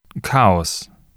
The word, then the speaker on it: Chaos: Chaos.